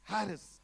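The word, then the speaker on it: Harris (χάρις): It is pronounced in Greek as Harris. See, silent.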